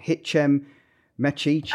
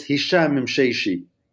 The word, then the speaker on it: Hichem Mechichi: Hichem Mechichi. – I love it. – Yes. Well, that tells me how badly, incorrectly I've done that. Go on. You do it. – It's Hichem Mechichi.